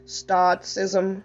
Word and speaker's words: stoicism: Sotism. Passivity. Sleepness. Alcohol and drugs. This is the spirit of escape. Let me repronounce the spirit under escape. Indifference, stoicism, passivity, sleepiness, alcohol, and drugs.